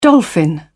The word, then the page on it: dolphin: British English